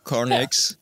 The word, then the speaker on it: Carnyx: Is it Carnyx? – Yes, Carnyx.